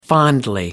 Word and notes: fondly: American English

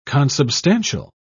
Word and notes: consubstantial: British English

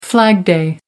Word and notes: flag-day: American English

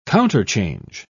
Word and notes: counterchange: British English

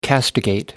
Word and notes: castigate: American English

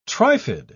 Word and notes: trifid: British English

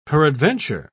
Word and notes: peradventure: British English

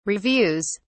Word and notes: reviews: British English

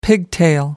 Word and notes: pigtail: American English

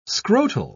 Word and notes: scrotal: British English

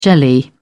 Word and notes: jelly: British English

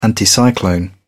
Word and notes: anticyclone: British English

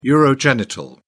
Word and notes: urogenital: British English

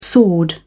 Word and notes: sword: British English

